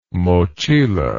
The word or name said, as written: mochila